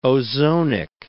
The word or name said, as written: ozonic